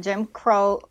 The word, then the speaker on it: Jim Crow: Yeah, um, like Christian, I knew about segregation, but the Jim Crow, did I say that right? Jim Crow yeah, law Jim Crow.